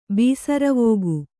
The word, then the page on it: ಬೀಸರವೋಗು: bīsaravōgu